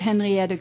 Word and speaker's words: Henriette: My name is Henriette Groot. That's spelled H-E-N-R-I-E-T-T-E. Groot, G-R-O-O-T.